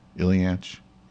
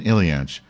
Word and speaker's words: Ilyich: Did I pronounce that right? Ilyich? Yes, no, someone with a brain. Don't lie. No time for jokes. Ilyich or what? Yes, thank you. The Death of Ivan Ilyich.